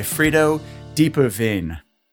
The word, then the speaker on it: Diepeveen: I'm delighted to be joined today by Frido Diepeveen. – Well pronounced. – Oh, thank you.